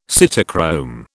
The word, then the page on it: cytochrome: British English